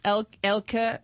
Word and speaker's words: Elke: You can call Elke, Elke, but just don't call me Elk. I hate when people do that. Because they think like the E is silent. I'm like, no, it's not.